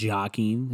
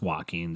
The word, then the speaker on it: Joaquin: I know how to pronounce his name. It's not Joaquin. Is it Joaquin? It's Joaquin, dude.